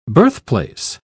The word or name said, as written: birthplace